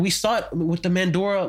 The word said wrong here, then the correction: Maduro: like, am I pronouncing that right? With the Venezuelan, it was instead of what? – Maduro. – Maduro, sorry.